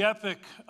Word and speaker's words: epoch: It was the epoch of belief." By the way, the proper pronunciation for that is epoch, but I'm going to say epic because that's what everybody else says.